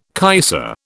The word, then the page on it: kaiser: British English